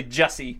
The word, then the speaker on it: Jussi: But I'm gonna go Uusi Jokinen. – Thank God. Yeah. I wanted to call him Juicy so bad. You have no idea. – It's actually Jussi.